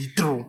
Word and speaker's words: Heathrow: You tell me Heathrow. That's how to pronounce it, man. It's like Heathrow. – Wait till they realize it, brother. – Heathrow, man. – Please, our listeners in the UK, please. – You say that he threw.